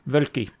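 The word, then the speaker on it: Woelki: Yeah, Wölki.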